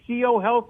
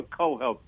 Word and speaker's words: Co-Health: Is it CO Health or Co-Health, John? – Co-Health. It's short for Connected Health Solutions.